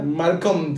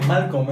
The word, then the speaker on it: Malcolm: Malcolm, el eh, eh, del medio. No se dice Malcolm, es Malcolm.